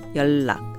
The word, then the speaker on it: 연락: It's actually spelled 연락, but it's pronounced 연락.